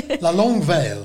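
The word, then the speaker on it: La Longue Veille: One tradition, for example, that has died out is La Longue Vie... I'm going to say this wrong. – La Longue Veil. – La Longue Veil. Thank you.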